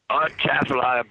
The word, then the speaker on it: Atchafalaya: Am I saying that? Am I pronouncing that properly? – Yep. Atchafalaya Basin.